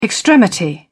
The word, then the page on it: extremity: British English